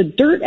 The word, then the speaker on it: Dyrt: And then I've also used the Dirt app. It's spelled D-Y-R-T, mm-hmm.